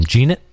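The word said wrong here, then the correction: Jeannot: The rookie for the Predators. – Oh, Tanner Janot. – Janot, there you go.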